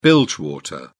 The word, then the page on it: bilge water: British English